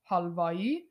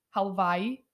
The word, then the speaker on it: Halvai: But if you want to say it like you're a true Iranian, Halvai. If you want to say it like you're an American, think Hawaii. Halvai.